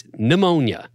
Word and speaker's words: pneumonia: It starts with a P, first of all. Where's the pneumonia coming in? The P is silent, and then there's the N. It's pneumonia.